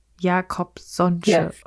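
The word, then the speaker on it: Jacobsonsche: Also was viele ja schon mal vielleicht gehört haben, ist dieses Jakobsche Organ. Spricht man das so aus? – Jakobsonsche Organ.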